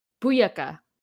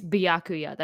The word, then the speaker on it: Byakuya: I used to say his name wrong on purpose because I hated him that much. Buyaka. But it's Byakuya. That's his actual name.